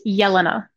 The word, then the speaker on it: Jelena: My name is pronounced Jelena, so with a soft J. – Oh. – Wow, it's an Eastern European name.